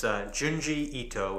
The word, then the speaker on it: Junji Ito: So again, it's Junji Ito, which is spelled J-U-N-J-I-I-T-O.